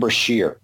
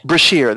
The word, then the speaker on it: Brashear: Adam Beskar? How do you pronounce it? – I thought it was Adam Brashear, the Blue Diamond. – Brashear, that's his name.